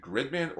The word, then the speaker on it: Gridman: Now hang on, is it Gridman or is it Grid-O-Man? – No, it's Gridman. It's just Japanese pronounce it Gridman.